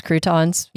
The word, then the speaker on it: croutons: It's like crotons. Is that how you say it? Crotons? – Croutons.